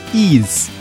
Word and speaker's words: Ys: Okay, so both Rick and Veritex have told me on Discord that how to pronounce the game is actually Ease. So YS is supposed to be pronounced Ease.